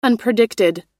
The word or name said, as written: unpredicted